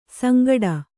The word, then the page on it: ಸಂಗಡ: sangaḍa